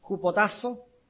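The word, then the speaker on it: hupotasso (ὑποτάσσω): Aquí la palabra someterse, del griego hupotasso, acento en la A con doble S, hupotasso, subordinar, reflexivamente obedecer, o al revés, obedecer reflexivamente.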